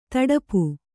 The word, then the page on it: ತಡಪು: taḍapu